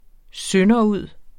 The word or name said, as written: sønderud